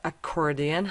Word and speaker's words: accordion: پس accordion تلفظ درست آکوردیون در واقع accordion هستش، accomplished هم به معنی ماهر و با استعداد هستش.